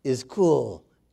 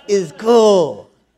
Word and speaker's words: ischuo: It's spelled I-S-C-H-U-O. And it is pronounced, is cool. Is cool.